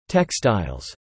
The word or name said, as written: textiles